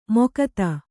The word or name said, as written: ಮೊಕ್ತ